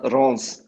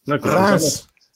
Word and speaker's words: Reims: Wird das jetzt Reims oder Reims ausgesprochen? Ich bin mir jetzt nicht ganz sicher, mein Schulfranzösisch. – Ja, es wird mir auch schwer dran, aber es wird Star Reims ausgesprochen.